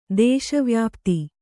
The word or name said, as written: ದೇಶವ್ಯಾಪ್ತಿ